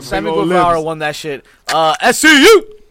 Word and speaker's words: Guevara: Guevara? – Sammy Guevara.